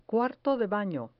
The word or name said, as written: cuarto de bano